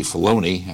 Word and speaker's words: Filoni: Filoni. And it's actually Filoni. I keep mispronouncing his name.